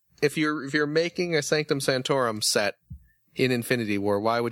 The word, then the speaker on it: Sanctum Sanctorum: Sanctum Sanctorum. – Mm-hmm. – Am I saying that correctly? – Yeah, the Sanctum Sanctorum.